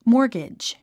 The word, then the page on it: mortgage: mɔ́ː r ɡidʒ; モーゲジ